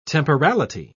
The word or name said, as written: temporality